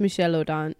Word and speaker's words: Michel Odent: Michael Odent, and he does a lot of work. – Michel Odent? – Did I say it wrong? – He's French, right? – Yeah. – I think it's Michel Odent.